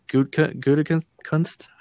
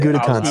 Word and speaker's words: Gutekunst: Gutekunst? How do you say that? – Brian Gutekunst. – I was hoping you just let him keep going. – That was atrocious, but he's been a lot more aggressive in free agency since taking over in 2018.